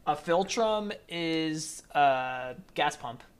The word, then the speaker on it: philtrum: Philtrum. – Philtrum? – Philtrum. – P-H-I-L-T-R-U-M.